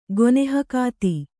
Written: ಗೊನೆಹಕಾತಿ

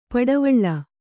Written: ಪಡವಳಿ

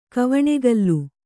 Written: ಕವಣೆಗಲು

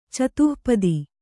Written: ಚತುಃಪದಿ

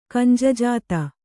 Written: ಕಂಜಜಾತ

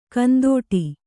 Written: ಕಂದೊಡಿ

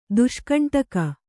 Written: ದುಷ್ಕಂಟಕ

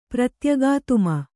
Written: ಪ್ರತ್ಯಗಾತ್ಮ

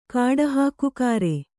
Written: ಕಾಡುಹಾಕುಕಾರೆ

